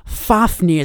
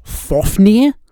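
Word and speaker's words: Fáfnir: It's obviously supposed to be a reference to, like, Fafnir from the Saga of the Volsungs, but Fafnir is actually pronounced Fofnir, so I've no idea what Fufud is supposed to be.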